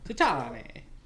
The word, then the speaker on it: Titane: How do you pronounce it? – Titane. – Yeah, French, the Italian film. Titane.